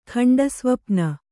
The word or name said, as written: ಖಂಡಸ್ವಪ್ನ